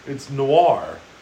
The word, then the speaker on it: Noir: Is it Noir? It's Noir. – Okay. – Like Pinot Noir? – Right, yeah.